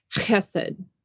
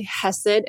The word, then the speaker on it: chesed: Now, before we move into this more properly, this is this guttural word, this chesed, as we would attempt to pronounce it. In English, many people just also say chesed, and while using that guttural is better, to save my throat and your ears, I'll be using the more Americanized English chesed today.